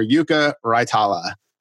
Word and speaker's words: Raitala: I'm Senior Digital Content Editor, and I am thrilled to be joined by the newest Loon, Defender Yuka Raitala.